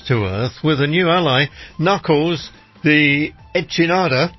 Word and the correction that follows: echidna: Echidna. Echidna. It's a tribe. Play some Sega. – It looks like it's spelled like Enchinada. – Okay, it's not.